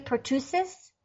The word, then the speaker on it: pertussis: So she's the one who wrote, and the title of the blog post, is the pertussis vaccine causing the recent outbreaks? – Yeah. – Did I pronounce that correctly? Pertussis? – Pertussis.